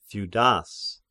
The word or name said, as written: Theudas